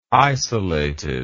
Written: isolated

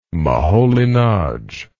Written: Moholy-Nagy

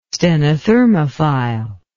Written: stenothermophile

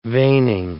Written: veining

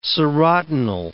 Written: serotinal

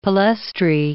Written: palaestrae

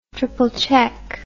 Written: triple check

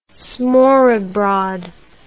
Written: smorrebrod

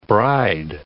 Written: bride

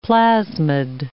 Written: plasmid

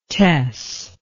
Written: tess

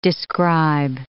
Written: describe

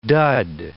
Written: dud

